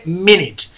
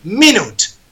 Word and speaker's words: minute: Entonces se dice minutes. Además, cuidado, cuidado porque minuto no se dice minute, se dice minute. Dos is como un templo. Mi, minute.